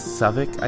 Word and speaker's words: Southwark: And if you're wondering what this Suffolk I keep speaking of is, it's Southwark, which is how I pronounced it for many weeks after arriving here.